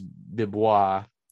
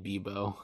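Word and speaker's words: Bibeau: Because I thought it was Bibois. It's spelled B-I-B-E-A-U. I thought it was Bibois. – It's bad to tell you that it is indeed Bebo.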